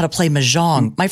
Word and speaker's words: Mahjong: It's, by the way, it's properly pronounced Mahjong.